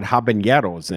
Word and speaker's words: habanero: And it gave me it unlocked the power to pronounce it correctly. – Habanero. Yes.